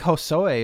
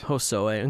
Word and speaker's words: Hosoe: I never really know how to pronounce his name here. What, what would you say? Shinji Hosoe. What would you. Yeah, I think Hosoe is perfect. Hosoe, okay. Um, all right, this is an interesting title.